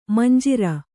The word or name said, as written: ಮಂಜೂರಿ